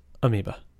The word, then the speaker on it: amoeba: Amoeba is spelled A-M-O-E-B-A. It's a weird word. – Amoeba.